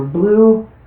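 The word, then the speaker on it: Blu: I have two Twitter accounts, one for Blue Dreams, which is actually you can find me there as at Jackie underscore Blue, and my older account TS Jackie.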